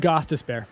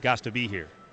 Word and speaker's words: Gostisbehere: Shane Gostisbehere. – Okay. Why can't that be Gostisbehere? – Because it's just not pronounced that way.